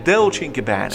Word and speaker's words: Dolce & Gabbana: Comunque si legge Dolce & Gabbana.